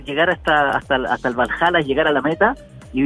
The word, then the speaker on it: Valhalla: Oye, ¿y este desafío vikingo OCR rumbo al Balalá? – Rumbo al, rumbo Valhalla, exactamente. Al, Valhalla. Oye, el Valhalla, ¿cómo se dice? ¿Bajala o bajala? Valhalla.